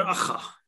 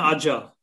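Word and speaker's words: Aja: Furt nevíme, jak se to vyslovuje, nebo už to víme? – Alexandra Acha. – Acha. Dobře, budeme mu říkat Acha. No, tak... Že ne Adja.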